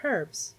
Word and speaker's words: herb: They look tree-ish, but they are in fact plants because they are herbs, or as Americans like to say, herb, which I do not understand.